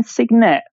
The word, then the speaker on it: Cygnet: I'm not sure you would have heard of this, but the Aston Martin Signet. It's a little Signet. – Oh, how do you spell that? – C-Y-G-N-E-T.